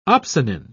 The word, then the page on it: opsonin: British English